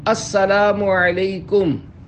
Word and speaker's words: السلام علیکم: سلام اور جواب سلام کا درست تلفظ یاد فرما لیجئے، پہلے میں کہتا ہوں آپ سن کر دہرائیے السلام علیکم، اب پہلے میں جواب سناتا ہوں پھر آپ اس کو دہرائیے وعلیکم السلام،